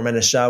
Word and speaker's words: Manasseh: However, Manasseh, depends on how you want to pronounce it. Manasseh or Manasseh would be more of the Hebrew pronunciation.